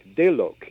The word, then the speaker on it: Deluk: Deluk is the mm-hmm. – way it's pronounced, and it means literally one who died and returned.